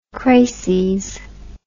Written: crases

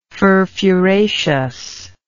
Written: furfuraceous

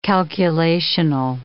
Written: calculational

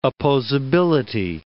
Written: opposability

